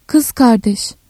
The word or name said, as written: kız kardeş